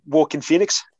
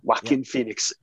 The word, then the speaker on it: Joaquin Phoenix: And I can't say his name right, but Joaquin Phoenix. – Joaquin Phoenix as Arthur Fleck, the Joker.